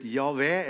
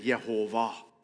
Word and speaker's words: JHWH: Y eller J-H-V-H. Det er liksom, hvordan skal man uttale det? J-H-W-H. Så har det enten blitt Javé eller Jehova.